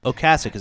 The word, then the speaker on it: Ocasek: Ocasek, is that how you say it? – That's how you say it.